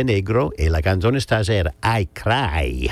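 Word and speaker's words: I Craj: But nonetheless, this song here that I'm going to play tonight is entitled, it's spelled I, and then C-R-A-J. So I cry, the way that an Italian would pronounce I cry in English.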